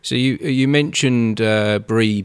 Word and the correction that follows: Brené: Brown. – Brené Brown, sorry. I'm terrible with pronunciations.